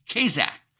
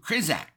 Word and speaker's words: chazaq: This phrase, found strength, comes from a Hebrew word, kazak, which is spelled C-H-A-Z-A-Q, kazak.